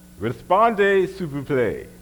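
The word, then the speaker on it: Répondez s'il vous plaît: Respondez, s'il vous plaît.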